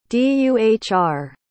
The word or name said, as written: duhr